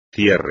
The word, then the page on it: cierre: espagnol